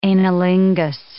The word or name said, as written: anilingus